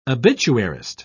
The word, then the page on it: obituarist: British English